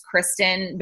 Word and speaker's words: Christin: And then my email is Christin, but it's spelled weird, C-H-R-I-S-T-I-N.